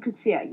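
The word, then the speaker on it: Coetzee: Coetzer. Is that how you pronounce the last name? – I beg your pardon, J.M. Coetzer, yes.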